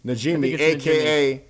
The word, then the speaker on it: Najimy: So Kathy, I always butcher her last name, Kathy Najimy, a.k.a. Najimy.